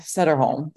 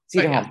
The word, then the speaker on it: Sederholm: So Britt Sederholm, that's your last name, right? I'm saying that right, right? – Sederholm. – Sederholm, yeah.